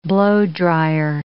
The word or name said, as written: blow-dryer